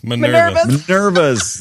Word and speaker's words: Minerva's: And if you've played games like, Minora's Den? Is that how you pronounce it? – Minerva's. – That's it.